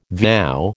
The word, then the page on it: vow: American English